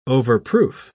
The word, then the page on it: overproof: British English